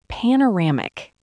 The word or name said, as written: panoramic